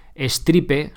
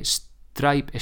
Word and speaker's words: Stripe: Stripe es una pasarela de pago, se escribe Stripe, sin la E, Stripe, Stripe, ¿vale?